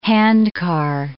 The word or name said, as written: handcar